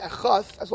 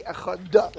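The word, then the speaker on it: echad (אחד): You can't say echada. It's not a word. Right? It has to be echad.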